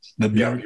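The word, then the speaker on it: Björk: Bjork, is that how you pronounce that? – Bjork song.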